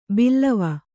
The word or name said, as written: ಬಲ್ಲವ